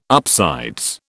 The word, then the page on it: upsides: British English